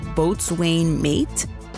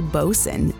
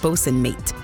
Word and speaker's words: boatswain: I said boatswain mate. Well, that's like 5,000% the wrong pronunciation. It's actually bosun, bosun mate.